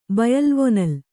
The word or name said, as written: ಬಯಲ್ವೊನಲ್